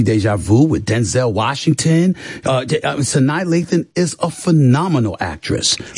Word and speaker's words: Sanaa Lathan: I don't even know how to say this woman. Sanaa Lathan. Okay. – Sanaa Lathan, yes.